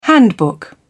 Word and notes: handbook: British English

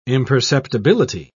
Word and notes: imperceptibility: British English

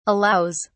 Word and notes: allows: British English

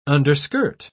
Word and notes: underskirt: British English

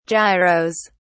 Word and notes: gyros: British English

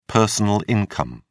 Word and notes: personal income: British English

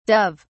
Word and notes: dove(s): British English

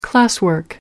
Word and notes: classwork: American English

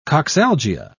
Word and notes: coxalgia: British English

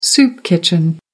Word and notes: soup kitchen: American English

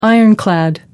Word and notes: ironclad: American English

